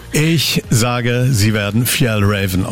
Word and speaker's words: Fjällräven: Und es wird richtig ausgesprochen, Fjällräven. – Fjällräven. – Also F werden im Deutschen als S ausgesprochen.